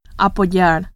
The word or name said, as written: apoyar